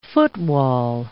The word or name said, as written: footwall